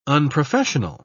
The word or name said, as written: unprofessional